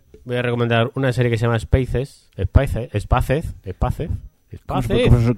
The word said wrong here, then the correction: Spaced: ¿Cómo se pronuncia, James? – Spaced. – Joder, es que como se nota en los angloparlantes. – Con D al final.